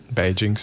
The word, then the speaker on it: Beijing: Beijing? – Beijing szerint.